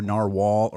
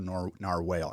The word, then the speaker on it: narwhal: It can be pronounced either narwhal or narwhal.